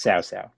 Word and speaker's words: Cao Cao: Oh, I thought the C had was a hard C. – So no, Cao Cao is not a thing. – Oh, that would be two farm animals. C is like a TS sort of thing.